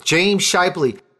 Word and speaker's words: James Scheibli: That's James Scheibli, and I'm going to spell it, S-C-H-E-I-B-L-I. How did I do, James? – That's perfect. You nailed it.